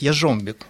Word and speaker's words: Яржомбек: И вовсе он не «яржомбек», а он «яржомбек», «яжомбек», если в польском произношении.